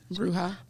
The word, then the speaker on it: Bruja: Bruja. Bruja. – Yes. See, I've been pronouncing it correctly.